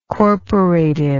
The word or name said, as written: corporative